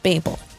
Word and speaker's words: Babel: You spell it like babe, like what you call your boo, with an L. So it's babe with an L, Babel.